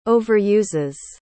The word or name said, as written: overuses